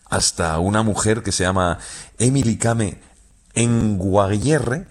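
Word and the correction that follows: Kngwarreye: lo he dicho mal. – Emily Kame. A ver, Emily Kame. – Con Guarreye. – Con Guarreye. Emily Kame con Guarreye, sí, sí.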